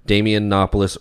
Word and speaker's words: Damianopoulos: And so this time when I wrote my name on the thing, I said Damianopoulos rhymes with Acropolis.